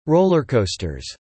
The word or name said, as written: rollercoasters